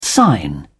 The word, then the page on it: sign on: British English